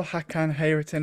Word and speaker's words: Hakan Hayrettin: So I suppose we'll start with the manager, because get my pronunciation right here, Hakan Hayrettin, is that right? – Yeah, Hakan Hayrettin, about that.